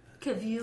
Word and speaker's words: Cavill: Cavill.